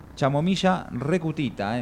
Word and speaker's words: Chamomilla recutita: Es un potente complejo botánico, ultra hidratante a base de extractos de Chamomilla recutita, eh, mirá cómo te lo dije, te lo dije bien, eh.